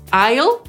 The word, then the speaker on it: I'll: I teoretycznie powinniśmy to wymawiać jako I'll, czyli tak jak przejście, na przykład w samolocie między fotelami, ale w zasadzie czytamy to zupełnie na co dzień, potocznie, normalnie jako Al, czyli tak jak imię Al, które może być skrótem na przykład od Alberta.